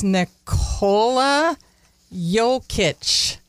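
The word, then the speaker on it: Nikola Jokić: I think it's Nikola Jokic.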